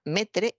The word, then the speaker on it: metre: Pues sí, así como suena, metre, M-E-T-R-E. E, es como se ha adaptado al español la palabra METRE, la palabra francesa, que se escribe maitre, se ha adaptado metre al español.